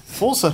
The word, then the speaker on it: Forza: Forza Horizon 2 was announced. – Alex is in the next room. Forza, well done for saying it right as well.